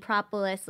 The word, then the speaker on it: propolis: honey and propolis.